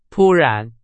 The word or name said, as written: 突然